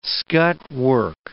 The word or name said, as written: scut work